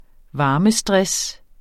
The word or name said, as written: varmestress